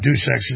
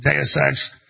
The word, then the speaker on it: Deus Ex: It's not Deus Ex, it's Deus Ex.